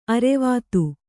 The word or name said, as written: ಅರ್ವತಿ